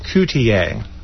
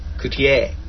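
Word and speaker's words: Cutié: His name is pronounced Coutier, even though it's spelled cutie. – That's why it's got the extensor sobre la E. Coutier.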